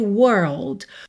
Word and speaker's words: world: Então, leave my world. World tem aí seu desafio de pronúncia. Vamos lembrar que você vai até o R da porta com a perna esquerda, enrolando mesmo o R, tá?